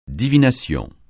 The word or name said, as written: divination